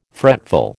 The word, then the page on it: fretful: American English